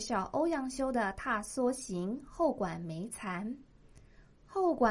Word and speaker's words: 踏莎行: 这句词的词牌名称念作《踏梭行》，梭是指梭草，而踏梭是指户外踏青郊游。